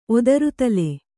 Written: ಒದರುತಲೆ

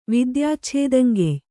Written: ವಿದ್ಯಾಚ್ಛೇದಂಗೆಯ್

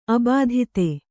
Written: ಅಬಾಧಿತೆ

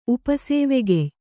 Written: ಉಪಸೇವೆಗೆಯ್

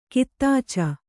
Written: ಕಿತ್ತಾಚ

